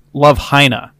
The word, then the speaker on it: Love Hina: It's actually pronounced Love Hina.